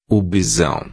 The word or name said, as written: bisao